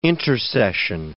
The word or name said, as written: intersession